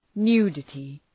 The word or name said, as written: nudity